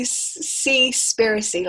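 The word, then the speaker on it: Seaspiracy: Cypressy, like conspiracy, but Cypressy.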